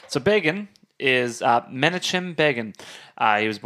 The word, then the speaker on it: Begin: Begin? – Rhymes with Reagan. – Oh, yeah, it makes more sense. Begin, Begin, Reagan, Palestine, terror on the airline.